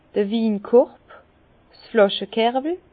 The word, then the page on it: d'r Winkorb, 's Flàschekerwel: Bas-Rhin; Herrlisheim